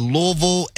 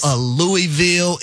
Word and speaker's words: Louisville: So I'm glad y'all get an opportunity to experience the city of Louisville. And most importantly, y'all are saying it correctly. Y'all aren't saying Louisville. It's Louisville.